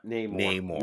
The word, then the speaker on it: Namor: Yep, Namor. You are Namor. – I'm a long A.